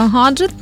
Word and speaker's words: гаджет: Пишеться гаджет, тому що у загальних запозичених назвах вживаємо букву, так, Г, тобто гаджет, геній, гість. І наголос, звичайно, у нас має бути, ну, гарно, так, гаджет на А.